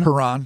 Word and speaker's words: Huron: Huron.